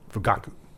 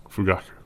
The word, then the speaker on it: Fugaku: I think the emphasis would be on the middle syllable. Fugaku. – Fugaku.